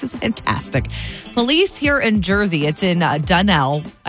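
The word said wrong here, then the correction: Dunellen: Dunellin. Joe, you tell her. – Dunellin.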